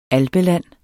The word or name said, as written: alpeland